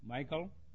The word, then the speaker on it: Michal: David's wife's name was Michal. Is that right? Saul's daughter? Michal? Thank you. It's easier for me to pronounce Michal than Michal.